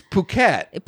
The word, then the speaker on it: Phuket: Is that pronounced fuck it, the capital of Thailand? – It's Phuket.